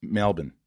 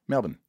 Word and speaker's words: Melbourne: Melbourne. – Melbourne. – Yeah. Like Americans say Melbourne. – Yeah, it's not right. Don't say Melbourne. – Even though it's spelled like that, no.